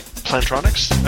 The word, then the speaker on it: Plantronics: Plantronics? I believe so. – Okay. I think I said Plantronics. Like, it's platonic. Like, no. Hey, they're not going to try and fuck you. – It's Plantronics.